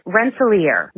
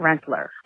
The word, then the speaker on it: Rensselaer: It is Oneonta, I think you said Oneonta, and it's Rensselaer, not Rensselaer.